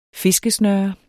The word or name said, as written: fiskesnøre